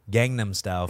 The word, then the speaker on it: Gangnam Style: Well, excuse me, Gangnam Style for the people who might not speak Korean. but is that how you guys pronounce it? – The white delegation says yes.